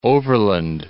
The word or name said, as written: Overland Park